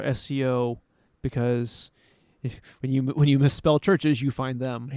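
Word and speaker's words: Chvrches: It's pronounced churches. – Okay. – So. – The go. – V is silent.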